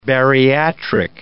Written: bariatric

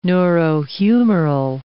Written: neurohumoral

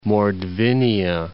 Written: Mordvinia

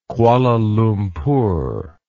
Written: kuala lumpur

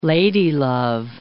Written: ladylove

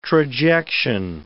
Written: trajection

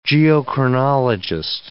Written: geochronologist

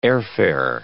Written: airfare

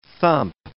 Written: thump

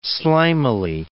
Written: slimily

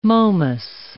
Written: Momus

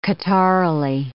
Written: catarrhally

